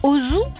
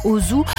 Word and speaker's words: Auzoux: Au 19e siècle, le docteur Ozu, alors c'est pas le cinéaste japonais, Ozu, A-U-Z-O-U-X, invente les écorchés.